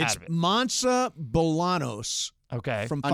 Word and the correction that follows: Monse: I'm pretty sure her name is pronounced Monse.